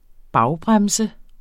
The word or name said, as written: bagbremse